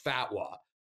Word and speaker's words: fatwa: We got $10,000 for the house, and everybody's got to have an existential crisis after reading, it's pronounced fatwa, the bin Laden fatwa.